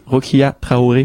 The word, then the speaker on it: Rokia Traoré: Rokia Traoré.